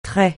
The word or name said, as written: trait